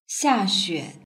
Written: xiaxue

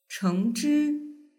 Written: chengzhi

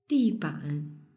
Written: diban

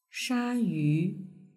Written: shayu

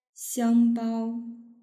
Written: xiangbao

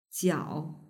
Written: jiao

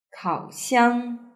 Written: kaoxiang

